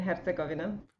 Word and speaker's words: Herzegovina: What's the proper way to say it? – Bosnia and Herzegovina.